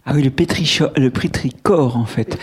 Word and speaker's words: pétrichor: D'ailleurs, dans le dernier tirage, vous n'avez pas le dernier tirage, j'ai rajouté la prononciation parce que c'est un CH euh, venu d'une racine grecque, donc c'est le pétricor.